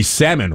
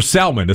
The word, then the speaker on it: salmon: Did you know it's also the time of the salmon run? Or salmon, as some people still like to pronounce the L in there.